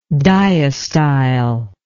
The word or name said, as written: dyostyle